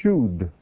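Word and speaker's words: shud (שוד): It's the word shud. Shin, vav, dalet.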